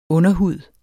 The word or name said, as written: underhud